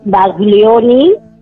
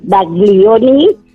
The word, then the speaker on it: Baglioni: Quisiera que me pusieras, por favor, para mañana, de Claudio Baglioni. – Sí. – No sé si lo digo bien. – Perfectamente. – Baglioni, pequeño, gran amor.